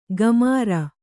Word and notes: ಗಮಾರ: Kannada; gamāra

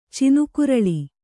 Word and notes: ಚಿನುಕುರಳಿ: Kannada

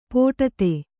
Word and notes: ಪೋಟತೆ: pōṭate

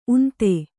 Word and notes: ಉಂದ: unda

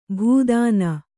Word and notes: ಭೂದಾನ: bhū dāna

